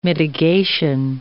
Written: mitigation

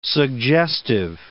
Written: suggestive

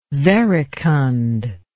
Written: verecund

